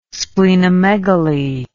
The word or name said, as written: splenomegaly